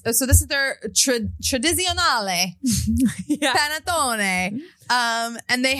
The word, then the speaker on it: Panettone: They sent me this gorgeous, how do you say it? Panettone? – Yeah. – Panettone, which is basically like a ginormous pastry cake.